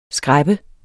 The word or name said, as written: skræp